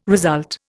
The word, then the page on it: result: Indian